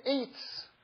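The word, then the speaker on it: Eitz (עץ): There's a very interesting teaching that I heard in the name of the Ari, which is that the word Eitz, which is the Hebrew word for tree, Eitz is spelled Ayin Sadi. And Ayin is one of two Hebrew letters that are silent.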